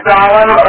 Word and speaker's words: golem: Now, a golem is pronounced either a golem, and it's spelled G-O-L-E-M, and it's either golem or golem.